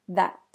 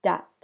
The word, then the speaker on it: that: The next example is that, that. The mispronunciation is dat, dat, or zat, zat.